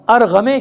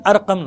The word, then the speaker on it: ارقم: اس نے بلایا ارغم شامی، ارغم لیکن ارقم نام ہے. کیا نام ہے؟ ارقم، لیکن وہ فارسی تلفظ کی وجہ سے ارغم، تو بعض جگہوں پہ ارغم لکھا ہے. عربی میں اس کا نام کیا ہے؟ ارقم. جیسے وہ قم کو غم کہتے ہیں نا، کبھی گم کہتے ہیں کبھی غم کہتے ہیں، وہ تلفظ کی بات ہے.